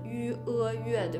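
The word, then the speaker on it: yue: 那你的意思就是说，我们比如说我的名字我写的是伊乌阿月，但其实呢，它的发音是 ü 阿月，对吧？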